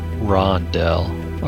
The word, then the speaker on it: Rondel: and if it looks like the thing that Rodel showed us, Rodel showed us like the broken one or something, right? – Are you saying Rodel? – Didn't you say his name was Rodel? – Rondel, R-O-N.